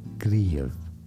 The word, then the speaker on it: Kyiv: It is to be said as Kyiv. – Kyiv. – Kyiv is how you say it in Ukrainian.